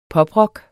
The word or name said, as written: poprock